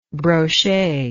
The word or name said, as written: broche